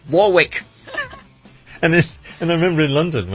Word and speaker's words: Warwick: We have the same with I love the way, because we have a place in the UK called Warwick. Okay, spelled Warwick, but we pronounce it as Warwick.